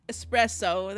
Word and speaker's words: espresso: Okay, so and we all know, and we know that it's about it's pronounced espresso. – There's espresso. no there's no, no X. X.